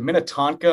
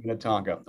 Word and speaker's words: Minnetonka: And you're from, what is it, Minnetonka? Am I saying that right? – Yes, correct. – Minnetonka.